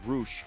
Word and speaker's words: Rush: Is it Rush or Roosh? How do you pronounce Roosh. – It? Roosh. That's right.